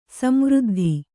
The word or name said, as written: ಸಂವೃದ್ಧಿ